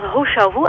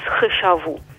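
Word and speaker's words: He Shou Wu: Het is, je zou het op zijn Nederlands als Hoshuhu uitspreken. Ja? Maar eigenlijk de juiste uitspraak is Hoshuhu.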